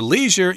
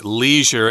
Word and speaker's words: leisure: I should also mention that leisure is the American pronunciation. In England, you'll often hear leisure.